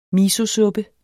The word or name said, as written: misosuppe